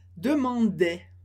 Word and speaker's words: demandaient: Les parents demandaient, mas a gente termina o som no E, sem pronunciar o ENT.